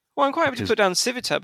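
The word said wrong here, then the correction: Sivota: but I've never heard of it and I don't know how to spell it. – Civota. – Oh, Civota. Okay.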